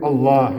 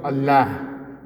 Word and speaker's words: Allah: But for the name of Allah, it's not Allah. It's what? It's Allah. For the name Allah, it's what? Lafzul jalala. It's tafkhim. It has fakhama.